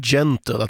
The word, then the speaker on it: Gentle: Ja, så då funderar jag under låten och kommit fram till att jag tror man ska uttala det här gentle, att den här nian ska vara ett G, och jag är väl lite för fåfäng för att låta den där fel uttalet hänga ute i eten.